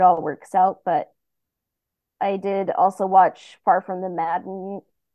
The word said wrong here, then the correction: Madding: See, I said it wrong. I always thought for years it was Far From the Maddening Crowd, but it is Far From the Madding Crowd.